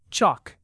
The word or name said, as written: chock